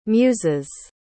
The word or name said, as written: muses